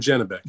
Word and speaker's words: Janibek: We just call him Janibek. – Janibek. So Janibek going for the WBO interim middleweight title. Danny Dignam from England is the opponent here. What do we make of this? Janibek is favored.